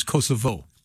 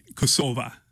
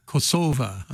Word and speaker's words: Kosova: And, teach us all how to properly pronounce what we've been pronouncing as Kosovo. – Right. Kosova. – Kosova. – Kosova. – Okay. Kosova.